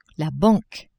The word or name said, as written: banque